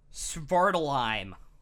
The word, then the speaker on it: Svartalfheim: Svartalheim. S-V-A-R-T-A-L-F-H-E-I-M. – Maybe that's what it was. – Svartalheim.